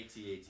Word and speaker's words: AT-AT: AT-AT and like you know what I mean? Like, thank you for saying AT-AT. That's the correct pronunciation.